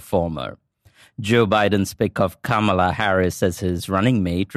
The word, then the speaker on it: Kamala: Kamala. – Just think of like a comma and add a la.